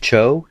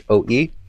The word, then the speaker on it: Choe: All right, so my pick is this artist, and I think it's pronounced David Choe, Choe. He has this video called Embrace Fearlessness, and his channel's only got 89,000 subscribers.